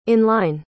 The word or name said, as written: in-line